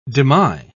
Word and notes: demy: British English

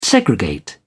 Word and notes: segregate: British English

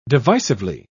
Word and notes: divisively: British English

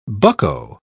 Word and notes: bucko: British English

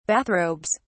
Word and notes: bathrobes: British English

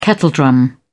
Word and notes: kettledrum: British English

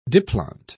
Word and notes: diplont: British English

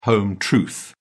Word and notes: home truth: British English

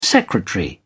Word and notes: secretary: British English

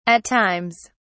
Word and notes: at times: British English

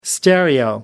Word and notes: stereo: American English

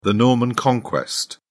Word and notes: Norman Conquest: British English

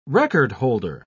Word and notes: record-holder: British English

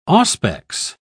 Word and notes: auspex: British English

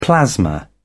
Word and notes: plasma: British English

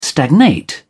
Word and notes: stagnate: British English

